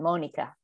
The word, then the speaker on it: Monica: The German and Spanish pronunciation, Monica.